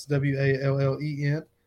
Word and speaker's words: Wallen: It's real simple, just Jay Wallen Fishing. It's Wallen, like Morgan Wallen. You know, I'm really glad that Morgan Wallen's gotten famous because now maybe people pronounce my name right.